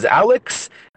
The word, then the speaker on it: Aleks: My name is Alex, but it's not spelled how you think it's spelled.